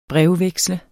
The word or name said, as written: brevveksle